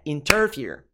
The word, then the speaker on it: interfere: E o ter não é a sílaba forte, não é a sílaba tônica, não é interfere, não é interfere. Fear é a nossa sílaba tônica.